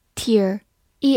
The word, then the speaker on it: tear: 好，此外呢，这个词也有另外一个发音，读作 tear，e a r 字母组合发 ear 这个音。